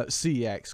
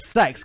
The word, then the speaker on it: CeX: Yeah, don't call CEX CEX on stream because it's pronounced sex.